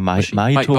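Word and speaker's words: Mahito: Und da stirbt dann auch die Mutter der Hauptfigur, Machito. Chito wird da, glaube ich, ausgesprochen. Die Hauptfigur, Machito? – Na, Maito, aber man, das Maito. I ist so ein bisschen stumm, deswegen ist, da verbiegen sie sich in der Synchro im Film auch so ein bisschen, das Machito zu nennen.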